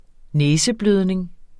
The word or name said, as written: næseblødning